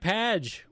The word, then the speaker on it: Padge: There's one from Sonic Padge, which is apparently pronounced like badge with a P.